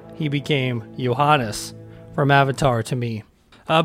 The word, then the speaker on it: Johannes: Johannes is basically if you would pronounce it with a Y instead of a J. – Okay. – In your way of pronouncing things, and Eckerström, good luck.